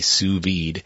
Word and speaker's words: sous vide: Well, it's spelled S-O-U-S and then V-I-D-E, sous vide.